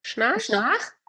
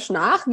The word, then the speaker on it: Schnarch: Magst du noch mal das Buch gerade sagen, was dir so weitergeholfen hat, von dem Daniel Schnarp? – Schnarch? Also den schreibt man Schnarch, wie Schnarchen. – Das finden die Deutschen immer so super lustig, ist ein Amerikaner, David Schnarch.